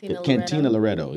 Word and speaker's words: Cantina Loretto: So last year we had it at Latina Carreto? What, how you say it? – Cantina Loretto. – Yeah, I said that backwards.